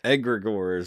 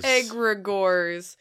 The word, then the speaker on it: egregore: How do you pronounce that word? – Egregores. – Egregores. Not Dementus. – It's like Gregor, but he's egregor.